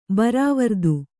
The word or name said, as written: ಬರಾವರ್ದು